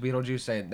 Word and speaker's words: Beetlejuice: Also, it looks like it's spelled like Betelgeuse or something, but it's pronounced Beetlejuice.